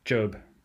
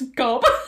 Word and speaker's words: Gob: Job. – Oh, I thought it was Gob.